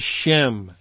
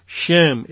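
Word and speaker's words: Shem (שֵׁם): And the word translated as renown, the Hebrew word, is Strong's number 8034, and it is the word that I would pronounce as Shem. Shem.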